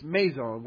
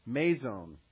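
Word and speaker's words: meizon (μείζων): First, this adjective spelled, it's meizon, which would be spelled in English M-E-I-Z long O-N, meizon, which is a masculine adjective, which is a comparative adjective to, we get it from the word megas, like mega, big, right?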